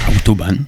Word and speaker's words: Autobahn: Autobahn.